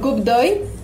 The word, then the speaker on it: coup d'œil: Y bueno, Coup d'œil es como se pronuncia y le falta una D a mi usuario de Instagram porque ya estaba tomado, ya no podía ponerlo.